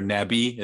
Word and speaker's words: Navi: It's called Kakao Navi, N-A-V-I, or Nabi as they pronounce it here.